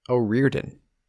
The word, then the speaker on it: O'Riordan: The second O is silent. O'Riordan is how they were pronouncing it over there.